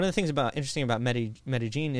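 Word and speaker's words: Medellín: But colloquially, in there, they pronounce double L's like a hard J. Like Medellin.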